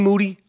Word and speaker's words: Moody: Moody. Last name rhymes with Dewey.